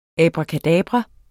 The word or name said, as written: abracadabra